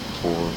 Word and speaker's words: Corps: F-I-F-E. – Drum Corps? – And Drum Corps. Corps is C-O-R-P. No, C-O-R-P-S. But it's pronounced Corps.